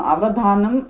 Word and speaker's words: अवधानम्: अवधानं ल्युडन्तपदम्, अव उपसर्गपूर्वकः, धा धातुः, न तु दा धातुः, तत्र उच्चारणे दोषः मा भवेत्. तत्र धानं भिन्नं पदं, दानं भिन्नं पदम् इत्युक्ते अत्र दा, किन्तु दा धातुः धा धातुः द्वयमपि जुहोत्यादिगणीयधातु धातुः एव.